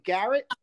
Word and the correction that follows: Geralt: Geralt, I mean, yes.